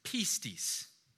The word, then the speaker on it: pistis: and that is pistis. All right, we'll put it on the screen so you can see what that looks like. Maybe take note of it. Pistis would be how it is pronounced.